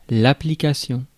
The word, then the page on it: application: [ˌæ.plə.ˈkeɪ.ʃən]